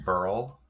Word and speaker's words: beryl: Beryl. – Beryl. I should remember it's pronounced like Earl.